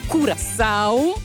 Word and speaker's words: Curaçao: Seguiremo anche per questa sera, insomma, una delle partite del mondiale, Germania Curaçao. Mi correggete se sbaglio la pronuncia, tutto giusto? – Non sbagli mai.